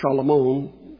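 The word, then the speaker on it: Shalomon (שְׁלֹמֹה): Shalomon, is actually how Solomon's name is pronounced in Hebrew.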